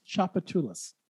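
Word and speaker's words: Tchoupitoulas: this one's pronounced Chapatulas. Chapatulas. – Mm-hmm. – That does not look like Chapatulas.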